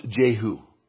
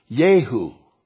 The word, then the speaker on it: Jehu: Now, in Hebrew, it's not pronounced Jehu. I'm just pronouncing it like that because that's how it looks in English. Jehu. In Hebrew, it's pronounced Yehu.